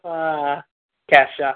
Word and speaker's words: Kesha: Yeah, I'll go with Kesha.